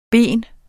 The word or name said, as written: BENS